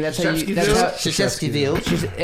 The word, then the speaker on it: Krzyzewskiville: Krzyzewskiville.